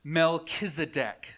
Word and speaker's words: Melchizedek: Melchizedek. Say it with me. Melchizedek.